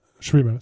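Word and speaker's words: Schwimmer: ¿Cómo se pronuncia eso? Schremer, está bien? Schremer, sí.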